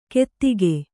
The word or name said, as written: ಕೆತ್ತಿಕೆ